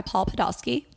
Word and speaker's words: Paul Podolsky: So we are joined here by Paul Podolsky.